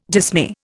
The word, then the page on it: dismay: Indian